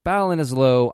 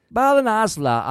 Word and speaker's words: Ballinasloe: Well, let's go to the year 2021 and picture ourselves in Always Sunny Ireland, Ballinasloe, Ireland, to be precise. And I know I got that right. Don't fucking come at me in emails telling me I did not pronounce that right. Ballinasloe, Ireland, to be precise.